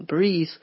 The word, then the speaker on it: breathe: This time we have very similar spellings, but the additional E in breathe changes the pronunciation.